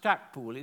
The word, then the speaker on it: Stackpole: So on a similar principle, I've called the house Stackpole, but it's pronounced Stackpool, it's spelt Stackpole.